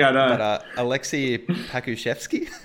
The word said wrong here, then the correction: Pokusevsky: Yeah, Alexei Pokusevsky.